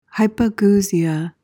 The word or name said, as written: hypogeusia